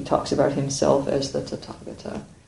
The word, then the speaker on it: Tathagata: Everything is pronounced as T. Don't be confused by the H in the middle. – Okay. – It's probably slightly different in correct Pali, but we say Tathagata.